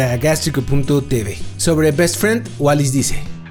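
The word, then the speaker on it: Wallice: La siguiente canción se llama Best Friend y es de Wallis. Wallis, que se escribe como Wally, W-A-L-L-I latina C-E. O sea, como una mezcla entre Wally y Alice. Es Wallis.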